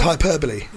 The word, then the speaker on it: hyperbole: What's the other one that everyone always gets wrong? A hyperbole. And it's hyperbole.